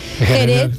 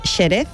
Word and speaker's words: Jerez: Jerez en principio era con una X, pero ahora se escribe con J y se pronuncia con J. Nadie dice Xerez.